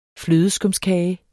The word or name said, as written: flødeskumskage